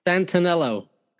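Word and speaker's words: Santenello: Peter Santello, am I saying that right? – Santanello. – Okay, Peter Santanello, the YouTube travel vlogger, you gave him a tour of New York's Hasidic community in a 15-episode series.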